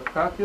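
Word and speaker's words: бхакти (भक्ति): Бхакти. Бхакти – это вера, но не только. Бхакти означает, на самом деле, любовь. – Бхакти, бхакти, бхакти. Произносится бхакти. Да. А пишется бхакти, Б-Х-А-К-Т-И.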